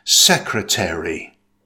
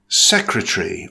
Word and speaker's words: secretary: In British English, it's pronounced with three syllables. Stress the first syllable. It's pronounced secretary.